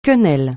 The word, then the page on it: quenelle: francais